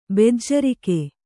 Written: ಬೆಜ್ಜರಿಕೆ

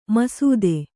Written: ಮಸೆತ

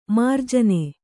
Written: ಮಾರ್ಜನಿ

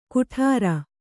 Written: ಗುಡಾರ

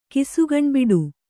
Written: ಕಿಸುಗಣ್ಬಿಡು

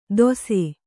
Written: ದೋಸೆ

